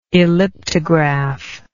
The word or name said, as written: elliptograph